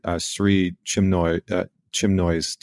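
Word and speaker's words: Sri Chinmoy: And so was that part of, hopefully I say his name right, Sri Chimnoy's teaching? Is that, am I saying that right? – That's perfect. Yeah.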